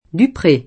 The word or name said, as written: Dupré